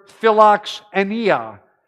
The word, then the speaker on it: philoxenia: And it rhymes with Philadelphia. So we have the word Philadelphia, which is an affectionate love for your brother. This word, philoxenia. We would pronounce in Greek, Philadelphia. So Philadelphia, philoxenia. The author in Hebrews, verse 1, is love your brother.